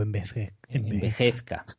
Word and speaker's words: envejezca: sea una película que envejeza especialmente. – Envejeza o envejezca. Eso he dicho envejezca. Has dicho envejeza. – Pero porque he pronunciado mal.